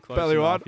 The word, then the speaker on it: Bellouard: Is it Bellouard, if I said it right? – Bellouard. – Bellouard. – Yeah. – Oh, sorry, guys. Yeah. My name's close pretty enough.